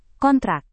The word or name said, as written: kontrak